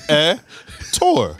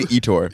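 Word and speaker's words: Ettore: It's two syllables. Eh? Tor. – I understand that. – It's not that difficult. – I'm saying everyone. – So many people say Etor.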